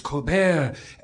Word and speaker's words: Colbert: So I asked him, is it Colbert or Colbert? And he said, it's the French, it's Colbert.